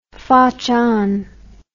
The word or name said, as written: fotchon